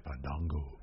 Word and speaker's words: Fandango: I do like that Fandango corrected him on the pronunciation of his name. – Yes. – We haven't seen that in a while. – I know. – It's actually Fandango.